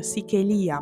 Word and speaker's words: Sichelia: o meglio Sichelia, ma non c'è l'accento sulla I, Sichelia-lang, sono sempre io.